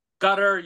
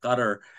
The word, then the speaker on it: Qatar: And I met one of the folks from Qatar and I asked him, how do you pronounce the name of your country? He said, you could do Qatar, you could do Qatar, you could do the more guttural Arabic gutter.